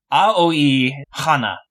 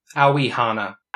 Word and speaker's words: Aoi Hana: To get into characters, I guess I'll lead things off, though, with talking about the main character, Aoi Hana. – Aoi Hana. – Actually, Tucker explicitly said it is not pronounced as one syllable, it's three syllables.